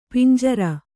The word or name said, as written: ಪಂಜರ